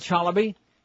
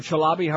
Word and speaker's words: Chalabi: Ahmed Chalabi, or Chalabi, however you want to say it. They keep changing the pronunciation like Tony Dorsett.